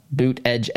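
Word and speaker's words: Buttigieg: Mayor Pete, boot edge, as I've learned is the right way to say that.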